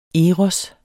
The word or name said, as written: eros